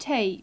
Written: tape

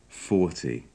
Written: forty

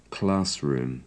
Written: classroom

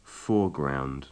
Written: foreground

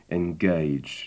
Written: engage

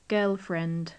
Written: girlfriend

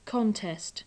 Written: contest

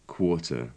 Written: quarter

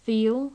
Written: feel

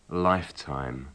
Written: lifetime

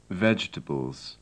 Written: vegetables

